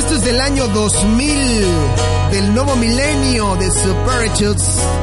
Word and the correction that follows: Parachutes: Oh, bueno, Parachutes.